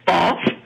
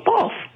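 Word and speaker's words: Bawlf: Isn't there a town called Bolf? I think it might be spelled B-A-W-L-F. I could be mistaken, but I think there's a town in Alberta called Bolf, Alberta. And that rhymes with golf.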